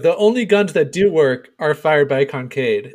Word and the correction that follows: Kincaid: If he fires a weapon, then you know that it's going to do some damage. – Kincaid. – What? I got the name right, man. Okay, why are you messing with me here? – We're slowly improving your pronunciation.